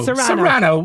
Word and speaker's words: Cyrano: Is it Cryano? How am I pronouncing this? – Serrano.